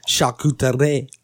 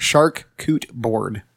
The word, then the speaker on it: charcuterie: The pronunciation, the true pronunciation, charcuterie. – Yeah. – Charcuterie. – Shark coot board. – Shark coot board? – Shark coot board. – No. It's a charcuterie board. – Charcuterie. – There's no R.